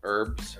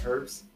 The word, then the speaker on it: herbs: Oh, and not herbs. – But over in England, they use herbs. – Yeah. – The language that which they gave us, they pronounce the H, and we don't.